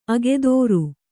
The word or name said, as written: ಅಗಧರ